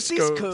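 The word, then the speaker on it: Sisko: Cisco, Cisco, dijo, lo dije bien. Cisco.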